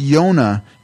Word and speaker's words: Jonah: The name Jonah is pronounced Yonah in Hebrew, quite similar to Yanua.